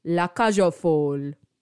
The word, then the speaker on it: La Cage aux Folles: It was La Cage, which I did say, but it's aux folles. La Cage aux folles. Anyway, I think I've now said that correctly.